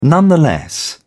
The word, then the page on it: nonetheless: British English